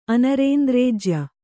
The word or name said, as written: ಅನರೇಂದ್ರೇಜ್ಯ